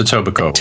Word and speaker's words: Etobicoke: Pronounced Etobicoke.